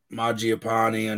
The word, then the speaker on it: Mangiapane: And now you have Mangiapane on on um, Connor's wow.